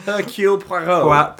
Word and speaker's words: Hercule Poirot: Hercule Poirot.